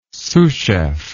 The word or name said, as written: sous chef